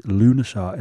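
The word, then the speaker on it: Lughnasadh: I found that this is how you pronounce it. Lunasar, it's spelled L-U-G-H, N for November, A-S-A, D for Delta, H. Lunasar. But to look at it phonetically, it says Lugnasadr.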